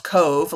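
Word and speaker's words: Cobh: Cork and Cove, which it looks like Cove with an H, but it's pronounced Cove, like a little cove.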